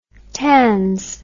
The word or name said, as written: tans